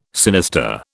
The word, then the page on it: sinister: British English